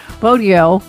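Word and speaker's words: Bogyo: One trick is Bodio like rodeo, which isn't 100% precise, but it's close enough and so much better than Bozo that I'm happy to take it.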